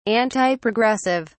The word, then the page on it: antiprogressive: British English